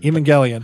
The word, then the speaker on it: Evangelion: Evangelion.